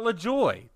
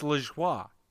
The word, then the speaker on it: Lajoie: Not LaJoie. No, it's LaJoie. – LaJoie. Are you sure? – Yes, it's a French name.